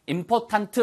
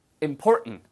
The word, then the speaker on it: important: 발음은요. important 이렇게 하지 마세요. import 하고 옆에 누가 important. It's an important situation ever.